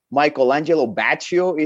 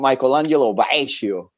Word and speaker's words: Michael Angelo Batio: Thanks lo- yeah, I gotta I gotta thank Chris because he actually taught me how to pronounce his name properly. I used to say, call him Michelangelo Baccio.